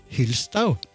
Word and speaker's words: Hylstau: Hvordan uttaler vi ditt tyske etternavn? – Eh, Markus Hylstau. Det er, det er, ikke så vanskelig. Og du har gikk det uh, veldig bra.